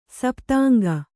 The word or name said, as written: ಸಪ್ತಾಂಗ